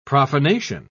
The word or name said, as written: profanation